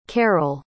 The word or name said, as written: carroll